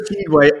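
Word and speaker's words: Tshiebwe: Stiebwe. How do you say it? Oscar Stiebwe. There you go. – Oscar Stiebwe, a rookie, nailed it.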